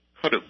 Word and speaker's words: Karuk: And what is the correct way to pronounce your tribe's name? – Cutuk. – Cutuk. It's spelled K-A-R-O-K. – K-A-R-U-K. – U-K. Okay, I had it as an O.